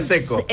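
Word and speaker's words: Džeko: Seco, ese se pronuncia Seco.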